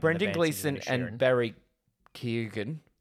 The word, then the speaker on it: Keoghan: Barry Keoghan is apparently how we're pronouncing that.